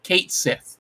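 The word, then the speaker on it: Cait Sith: So Square just casually dropped it on their some stream or a Twitter feed or whatever that it will be pronounced K Kate Sith, which in Final Fantasy VII Rebirth.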